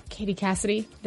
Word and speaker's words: Katie Cassidy: We will do our best to go find, what is it, Katie Cassidy? Did I say her name right? – Oh, yeah.